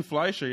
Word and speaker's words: Fleischer: Fleischer? – Ruben Fleischer.